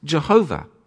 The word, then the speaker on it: Yahweh: Traditionally, it's pronounced Jehovah. But perhaps more accurately, the correct pronunciation is Yahweh or Yahawah. We don't know, actually, but it's God's personal name.